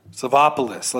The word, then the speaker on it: Savopoulos: Savopoulos. Are we in Germany? – No, we're in Washington, D.C. – Right on. – Savopoulos. – Savopoulos. – Say it correctly. – Savopoulos. Let's get into the show.